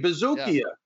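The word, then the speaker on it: Buzukja: Bazooka is how you pronounce it.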